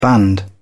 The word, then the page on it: band: British English